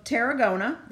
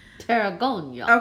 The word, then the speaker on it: Tarragona: In the Spanish city of Tarragona, that's not how you say it. – Tarragona.